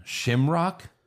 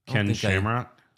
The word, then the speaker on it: Shamrock: Rebel Scum, has anyone ever commented on the way pronounces Ken Shamrock's name? Shimrock? – Ken Shamrock? – Ken Shamrock.